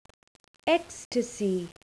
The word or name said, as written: ecstasy